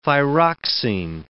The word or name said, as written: thyroxine